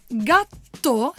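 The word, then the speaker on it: Gattò: Ecco, è stata rinominata Gatto, scritto G-A-T-T-O con l'accento.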